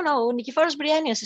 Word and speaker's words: Nikephoros Bryennios: So how does Nikephoros Bryennios, I'd probably say that name wrong, but how does No, you actually said it very correctly. No, Nikephoros Bryennios is correct.